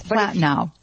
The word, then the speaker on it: Platino: Her last name, very difficult to pronounce, Poletino? – Yeah, it's Platino.